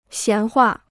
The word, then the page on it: 闲话: xián huà